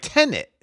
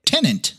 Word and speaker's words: Tenet: Tenet. – Tenant. – Hold on here. Let me look. – Tenet. Tenet. Tenet. It's like Tenet. – Tenet. Yeah. Tenet. – Yeah. – Not Tenant. Tenet.